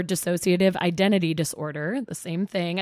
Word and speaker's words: dissociative: Tell me about disassociative. Did I say it right? – Or sort of dissociative. I think you might have added a syllable, but I liked it.